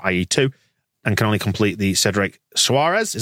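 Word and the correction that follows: Soares: Is that how we pronounce it? – Saws.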